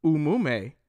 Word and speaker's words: Umume: Umume, pronounced Umume, originates from the Igbo language in Eastern Nigeria, meaning behavior, mood, or character.